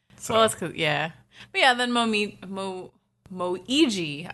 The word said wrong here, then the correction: Momiji: I always have trouble with his name because I want to say Momiji. – That is how you say it, Momiji. – But it looks like Moiji. – No, it doesn't. There's an M.